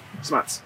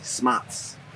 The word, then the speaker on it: Smotz: Smuts. – Smuts? – Smuts. – How do you spell it? – S-M-O-T-Z. It's like snot. – S-M-O-T-Z.